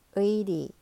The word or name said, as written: 의리